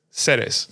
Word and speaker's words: Ceres: But on top of that, they also have belters living in certain asteroids, like Eros or Ceres? How do you say Ceres.